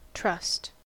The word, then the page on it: trust: American English